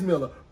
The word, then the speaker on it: Miller: And he was like, Miss Miller, no, the hell it ain't. And I'm like, oh, no, sir, I say my name is Miller.